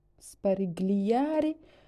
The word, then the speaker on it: sparigliare: I'm not very sure se sparigliare o sparigliare. I'll put in Google Translate. – Sparigliare. – Sparigliare. Okay, I don't need to pronounce G.